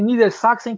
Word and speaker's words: Niedersachsen: É seleção de Niedersachsen. É assim que é, pronuncia? – Isso, é a seleção de Niedersachsen, que é a Baixa Saxônia.